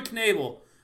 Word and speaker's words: Knebel: Oh, I do want to mention Corey Knebel, and you do pronounce the K.